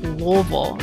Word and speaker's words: Louisville: Yep, and if you're in Kentucky, that's pronounced Louisville, right, Alex? – Right.